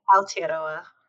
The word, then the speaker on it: Aotearoa: Yeah, Aotearoa.